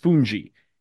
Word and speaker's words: fungi: It's spelled like fungi, but it's pronounced fungi.